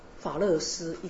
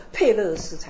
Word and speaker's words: 佩勒斯: 他从犹大那里就生了两个双胞胎，一个名字叫做法勒斯，一个叫做谢拉。其实法勒斯我们的翻译叫法勒斯，应该叫做佩勒斯才对，那个发音呢、以色列的前总统名字叫 Perez。